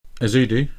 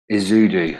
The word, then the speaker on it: Ezeudu: I always I can't pronounce his name. – Ezeudu. – Ezeudu.